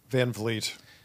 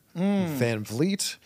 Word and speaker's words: Van Vliet: I think that if I were to do it again, I would have made my last name Vleet, so it would be phonetic. Van Vliet.